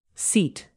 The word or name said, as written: seat